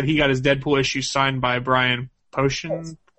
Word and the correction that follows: Posehn: Posehn. Posehn, that's how you say it. I always messed up.